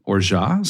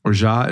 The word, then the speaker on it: orgeat: Orgeat is spelled O-R-G-E-A-T. So it doesn't look like orgeat, but it sounds like orgeat.